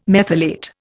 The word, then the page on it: methylate: Indian